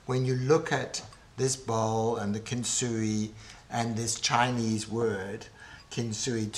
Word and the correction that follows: kintsugi: two words, kintsugi,